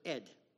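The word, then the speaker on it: Ed (עד): You pronounce it Ed, like a guy's name, Ed.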